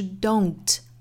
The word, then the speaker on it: don't: 我们注意 don't 这个发音，我们刚才讲到英音是一个 o 的音，那美音呢要注意是 don't.